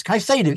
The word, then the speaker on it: Caicedo: Is equally inaccurate, by the way. It's Caicedo.